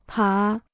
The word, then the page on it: 爬: pá